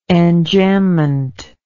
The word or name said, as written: enjambed